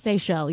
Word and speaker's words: Seychelles: Seychelles, yeah. – The Seychelles, yeah. The islands. – S-E-Y-C-H-E-L-L-E.